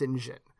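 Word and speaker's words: St. John: Apparently that's meant to be pronounced Sinjin. Wow. – Um, so I think maybe we're less pronoun we're we're not we're not familiar with it because it's a British pronunciation of it.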